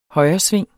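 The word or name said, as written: HØJRESVING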